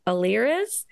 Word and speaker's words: Alirez: Andrew Alirez. – That's it. Oh. That's correct. I can't pronounce it. I always say Alirez, but for some reason I can't make the I the correct sort of I. So listen to how Jackie says it.